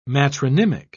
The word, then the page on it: matronymic: British English